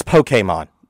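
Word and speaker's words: Pokémon: It's Pokemon. Please. There's no long E in Japanese. – Pokemon. – Just saying. It's Poke.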